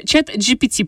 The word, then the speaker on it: ChatGPT: Значит, э, чат GPT, правильно говорить.